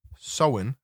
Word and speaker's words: Samhain: Oh, Halloween's origins date back to the ancient Celtic festival of Samhain, pronounced Samhain.